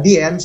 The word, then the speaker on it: Dien: Ini kalau berbicara tentang usaha fashionnya, Sandy mau nanya dulu nih, ini dibacanya Dien atau Din atau apa yang betul? – Untuk disebut apa di sebutannya Dien sih, Dien Fashion.